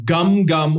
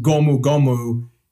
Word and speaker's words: Gomu Gomu: Gum Gum, or more accurately pronounced Gomu Gomu, means rubber in Japanese.